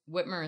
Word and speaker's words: Whitmer: It's Gretchen Whitmer. I'm pretty sure. – I think that's it. That's correct. – Yep. I don't want to say it wrong. – No, it's Whitmer is correct.